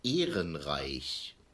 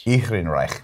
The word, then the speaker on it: Ehrenreich: Heinig, the correct pronunciation of the name is Ehrenreich.